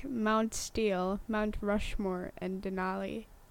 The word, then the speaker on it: Denali: Yes, it's Denali, also known as Mount McKinley.